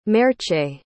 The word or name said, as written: merche